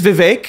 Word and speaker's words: Vivek: It is Vivek. He Vivek? Has made clear it, is Vivek. – It rhymes with cake.